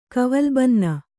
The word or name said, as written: ಕವಲ್ಬನ್ನ